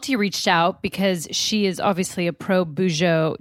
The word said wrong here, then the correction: Bujo: Do I say that right? Bujo journalist? – Or? Not at all. You're so, no. – Okay, so how like, do you say it? – Bujo. Bujo. Bullet journaling.